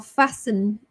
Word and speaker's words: fasten: F-A-S-T-E-N, when you are in a car, you need to, is it fasten or fasten your seatbelt? It's silent T, fasten your seatbelt.